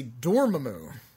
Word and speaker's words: Dormammu: They pronounce it differently in the TV series. They say Dormammu. With stress on the door part.